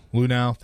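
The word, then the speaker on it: Luhnow: Houston Astros GM Jeff how do you say it? Lunau. Thank you.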